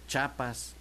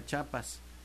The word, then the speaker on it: Chiapas: dígale por favor que no diga Chiapas, que es algo común, ¿no? Que no diga Chiapas, que es Chiapas, porque no se dice Chihuahua.